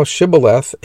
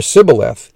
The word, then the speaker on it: Shibboleth: Then said they unto him, "Say now Shibboleth." And he said, "Shibboleth," or "Sibboleth," for he could not frame to pronounce it right.